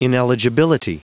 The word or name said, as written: ineligibility